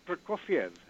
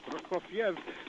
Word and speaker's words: Prokofiev: And one of them is Sergei Prokofiev. Three syllables, Prokofiev, but it's often pronounced Prokofiev, four syllables.